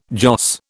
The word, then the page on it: joss: British English